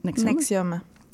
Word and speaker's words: NXIVM: qui s'écrit N-X-I-V-M, mais qui est prononcé Nexium.